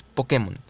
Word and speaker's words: Pokémon: Su pronunciación correcta es Pokémon.